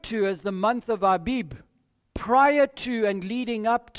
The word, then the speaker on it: Abib: Now that is a month referred to in the scriptures, and in particular in Deuteronomy, it's referred to as the month of Aviv. It might even be pronounced Aviv by some, but it's A-B-I-B, Abib.